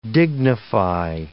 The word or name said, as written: dignify